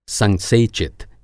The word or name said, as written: संसेचित